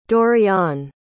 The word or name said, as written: Dorion